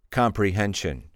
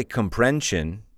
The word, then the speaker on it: comprehension: What about, for example, the word comprehension? Comprehension. Comprehension has four syllables. Comprehension. So that you don't say comprension.